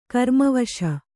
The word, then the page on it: ಗ್ರಾಮವಾಸಿ: grāma vāsi